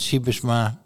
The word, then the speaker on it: Sijbesma: Schrijf cijfers spreek uit Cybersma. Nederlanders kunnen Sibisma uitspreken, buitenlanders wordt het Sibisma.